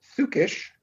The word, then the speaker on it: psychisch: In German, they would pronounce it psychisch, and it means psychological.